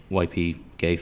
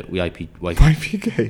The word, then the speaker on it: YPG: The British YP gay fighter, YP gay? – Well, that's like how the Kurds pronounce it, they go like, YP gay.